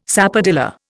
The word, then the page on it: sapodilla: Indian